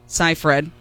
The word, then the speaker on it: Seyfried: And then there is the dramatized version that was on Hulu that she was played by Amanda Seyfried is how you say her name, but Seyfried.